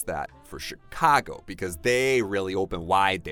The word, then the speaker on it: Chicago: Seattle Oh Seattle Seattle 好那最后要说的一个美国城市呢是芝加哥I want to talk about this one because the spelling is C-H-I-C-A-G-O,、mm. 但是它发音不发 Chicago. – Right, right, it's sh.